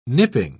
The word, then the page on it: nipping: British English